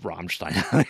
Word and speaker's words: Rammstein: Of course, yeah, I also have Rammstein here, because, of course, I'm sorry. – You gotta say it like that. That's how he says it. – Soundtrack is amazing. – Roll the R.